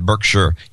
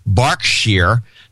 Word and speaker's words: Berkshire: It's pronounced Berkshire, not Berkshire.